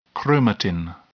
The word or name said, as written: chromatin